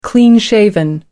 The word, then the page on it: clean-shaven: American English